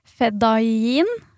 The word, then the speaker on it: Fedayeen: Han var også leder for militsen, som da heter Fedayeen, vil jeg tro du uttaler det. – Ja.